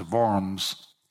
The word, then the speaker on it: Worms: The town was Worms, but if you pronounce it in German, it's Worms.